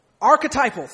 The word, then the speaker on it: archetypal: Archetypal. Thank you. See, it's good that we have elders who know what they're talking about to correct us young folk. Peter's archetypal, thank you, quality, or his main quality, is his unending youth.